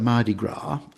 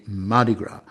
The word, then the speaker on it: Mardi Gras: Now, the important thing about Mardi Gras is that nowadays people are pronouncing it with a stress on the first syllable as if it was just one word, Mardi Gras. And listening to broadcasts from the Sydney Mardi Gras, I don't think I heard Mardi Gras, da-da-da, at all.